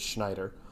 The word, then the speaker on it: Schneider: And yes, that's how you pronounce his last name, even though there's a Z and a J in it. It's Schneider. yeah, that one still baffles me.